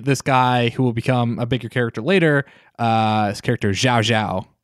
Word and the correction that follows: Cao Cao: Sao Sao. Sao Sao? Tiao Tiao? – Tiao Tiao. Yeah. Which is spelled C-A-O-C-A-O.